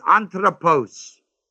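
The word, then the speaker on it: anthropos (ἄνθρωπος): And the word man is anthropos, A-N-T-H-R-O, long O, P-O-S. And that word anthropos may mean, it does mean man or woman.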